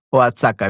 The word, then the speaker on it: Oaxaca: Va a México, Osaka. Ok, perfecto. Muy bien. O Oaxaca. Oaxaca, I think it's pronounced.